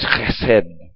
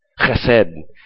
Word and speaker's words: chesed: In Hebrew, the word for goodness there, to say it in Hebrew, is chesed. Chesed. Okay, it's spelled like C-H-E-S-E-D.